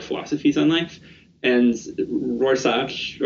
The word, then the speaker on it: Rorschach: There's a character in it whose name is Rorschach, and that's how that's pronounced, right? Like the inkblot test? – Rorschach.